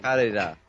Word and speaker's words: Kaneda: Kaneda. – Kaneda. – Yeah, that. – Because his name is Kaneda. – It sounds like you don't know how to pronounce Canada. – His name is spelled K-A-N-E-D-A.